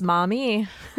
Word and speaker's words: Maumee: So in Maumee, Ohio, did I say that right? Is it Maumee? – You sure yes. did. It's Maumee.